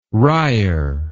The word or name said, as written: wrier